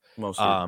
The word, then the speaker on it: Mostert: Mostert.